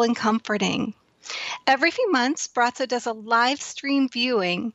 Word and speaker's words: Braco: Many of you may have already heard of Brazzo, who's known as the Gazer. His name is spelled B-R-A-C-O, and he has provided healing, hope, and comfort to people around the world solely through his gaze.